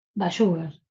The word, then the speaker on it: Bayugar: Sí, sí, en un 85%. – Faltó sí. – El Bayugar. – El acento en la U, claro.